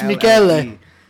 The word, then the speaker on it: Michelle: We just had a cousin get married, and his name is spelled M-I-C-H-E-L-L-E. – It's Michele. – It's Michele. – And how many times did you hear Michelle?